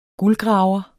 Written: guldgraver

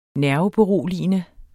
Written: nerveberoligende

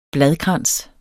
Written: bladkrans

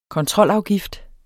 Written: kontrolafgift

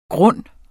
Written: grund

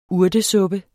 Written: urtesuppe

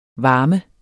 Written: varme

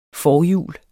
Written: forjul

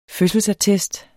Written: fødselsattest

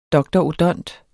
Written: dr.odont.

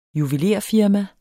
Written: juvelerfirma

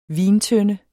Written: vintønde

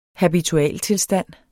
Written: habitualtilstand

